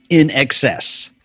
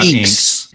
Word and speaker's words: INXS: All right, so for those who are not familiar with this band, it is actually pronounced INXS. – Right. – It's not INXS. – No. – Nothing screamed you were out of touch more in the 80s than calling these guys INXS.